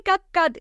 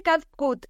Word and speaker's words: CapCut: En TikTok me dicen, no se dice CapCat, se dice CapCut.